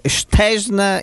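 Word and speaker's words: Szczęsny: della Juventus vedrebbe eh, Stesn in porta. Chi? Così dicono che si pronuncia in polacco. Ah, Vabbè, Vabbè Scesni importa.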